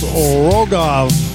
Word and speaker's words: Rogov: I believe it's pronounced Rogov, R-O-G-O-V, Rogov.